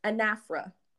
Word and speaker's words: anaphora: So the term I was supposed to teach my students is a literary term called anaphora. – Mm-hmm. – So it's A-N-A-P-H-O-R-A.